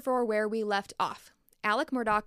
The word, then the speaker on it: Alex: And by the way, while I was editing part 2, I caught a few times where I accidentally said Alex instead of Alec. It's confusing because it's spelled Alex, but it's pronounced Alec.